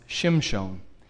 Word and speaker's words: Shimshon: Now, in the original language, this name is pronounced Shimshon. And what it means is little son, S-U-N.